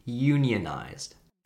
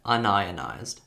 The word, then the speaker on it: unionized: And there's actually two ways that you can pronounce this word. You can pronounce this word as unionized, or you can pronounce it as un-ionized.